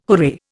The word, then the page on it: hooray: Indian